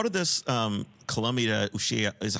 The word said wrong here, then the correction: Ushuaia: how do you pronounce the other word? Ushia? – The other country. – Ushuaia. – Ushuaia.